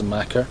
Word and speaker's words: machair: So you then end up with this fertile plain, which is the Macca, which is spelt M-A-C-H-A-I-R, which is a good Gaelic word meaning fertile plain.